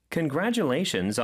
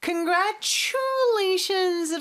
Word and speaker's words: congratulations: Congratulations 라고 했는데 Congratulations 라고 하고 싶을 거예요. 근데 요 TU가 합쳐지면 추 발음이 나잖아요. 근데 여기는 T 발음보다는 그냥 지읒 발음처럼 해주는 경우가 많습니다. 그리고 grad 요 부분에 강세가 있어요. Congratulations 또 더 강한 강세가 또 뒤에 있죠. 그래서 congratulations 라는 거 이거 기억해 주시고 congratulations on your 이렇게 연음이 두 번 됩니다.